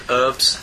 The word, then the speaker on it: herbs: And yet they never pronounce the H in herbs.